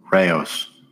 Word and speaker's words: Rao's: I went to Target today, and I saw Rouse. That's how you say it, right? Rouse? The tomato sauce brand? – Rao's. – Pardon. I'm not very up to date with my names, it seems. But Rao's, yeah.